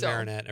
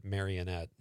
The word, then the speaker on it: Marinette: Well, I live in Marinette. Everybody pronounces it Marionette.